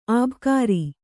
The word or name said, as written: ಅಬುಕಾರಿ